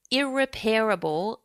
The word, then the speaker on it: irreparable: And the commentator talked about how a particular team had done irreparable damage. Now, how does one pronounce that? – Irreparable.